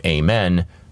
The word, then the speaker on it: amen: Just like it's fun to notice that the Hebrew pronunciation of amen rhymes with chow mein.